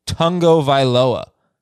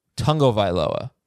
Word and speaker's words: Tagovailoa: There is a sneaky N in there, even though it's not in the actual spelling. It's Tagovailoa. Tagovailoa.